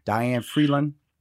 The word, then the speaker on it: Diane Vreeland: I want to say her name right. – Diane Vreeland. – I know I had to get that name right.